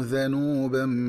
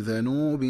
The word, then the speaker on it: ذنوبا: فإن للذين ظلموا ذنوبا مثل ذنوب أصحابهم، والذنوب النصيب من العذاب، ومن الخطأ قراءته بضم الذال هكذا ذنوب.